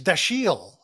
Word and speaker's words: Dashiell: Now, he's the correct pronunciation of his middle name, his first name was Sam or Samuel, is Dashiell Hammett. But I have never in my life ever heard anybody refer to him as Dashiell.